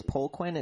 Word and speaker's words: Poliquin: Charles Poliquin. It's or Poliquin, depending on how you say it.